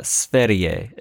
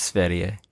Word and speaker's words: Sverige: It's Sverige is how they say it, I believe. – Is like the W pronounced like a V? Sweden? – No. No, it's Sverige.